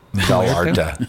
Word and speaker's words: Vallarta: I think you mean Puerto. – Puerto? – Vallarta. – Vallarta. Vallarta. – Vallarta. Your pronunciation is not too good.